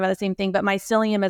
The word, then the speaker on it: mycelium: Am I saying that right? – Yeah, mycelium.